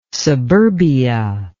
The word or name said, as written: suburbia